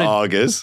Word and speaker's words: Aga: I cannot wait for you now to do a gig in England and do a bit about augers. There is a challenge. – It's called. You just spell it AGA.